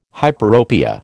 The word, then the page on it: hyperopia: American English